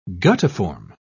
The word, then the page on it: guttiform: British English